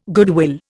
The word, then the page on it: goodwill: Indian